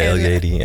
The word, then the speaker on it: El Yedi: Met El Yedi, zeg ik het goed? El Yedi. – Ja, El Yedi.